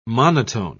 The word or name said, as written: monotone